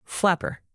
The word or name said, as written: flapper